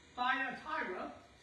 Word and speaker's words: Thyatira: Thyatira, said it right that time, is a wealthy town.